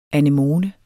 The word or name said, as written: anemone